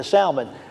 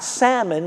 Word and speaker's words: Salmon: In fact, she marries a Jewish man by the name of Salmon. Well, there's a piece of trivia for you. You took that away. Who was Rahab married to? Married to Salmon. Not salmon like the fish, but Salmon.